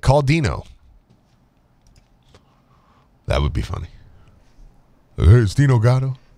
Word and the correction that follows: Gaudio: Dino Gaudio. – Just get his name right.